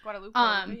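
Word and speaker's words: Guadeloupe: Guadalupe. I think that's how you say it. – Mm-hmm. Guadalupe, yeah.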